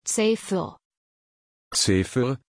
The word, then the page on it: Zephyr: German